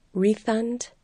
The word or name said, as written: refund